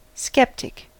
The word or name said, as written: sceptic